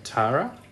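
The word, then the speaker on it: Tarah: But I've given, I think we pronounce her name Tara. – Susie Plaxton's character. – Yeah. – She did a bit of It's spelled like Sarah with an H, but with a T instead of an S.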